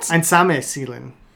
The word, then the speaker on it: Einsame Seelen: Einsam Seelen. – What? – Einsame Seelen. – Einsam. – Einsame. – Oh, my God. – Einsame Seelen. That's how you pronounce it in the original German, of course.